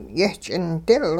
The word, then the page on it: yehch'indil: hupa